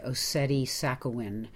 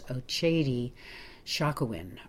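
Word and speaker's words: Oceti Sakowin: I stayed at what's called, if you were to look at it and just say it, it looks like Ossetti Sakowin, but it's pronounced by the folks there, I think, as Ochedi Shakowin or Shakowin, depending.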